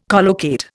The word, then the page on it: collocate: Indian